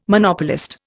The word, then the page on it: Monopolist: Indian